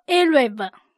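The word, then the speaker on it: Erev (ערב): 今天我们要看的字是晚上，读音作 Erev。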